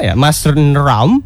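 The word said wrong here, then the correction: Mawsynram: Tapi gua tahu nih cara bacanya. – Mao Shin Ram. Yes, thank you.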